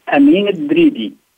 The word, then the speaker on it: Amine Dridi: And Amine, is your last name pronounced Dridi? – Yeah. Amine Dridi.